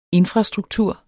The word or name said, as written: infrastruktur